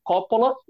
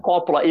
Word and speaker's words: Coppola: E aliás, aqui cabe um parênteses: vocês estão sentindo uma diferença entre a minha pronúncia e a pronúncia. Coppola é do jeito que a gente fala normalmente, mas a pronúncia está correta, é Coppola mesmo.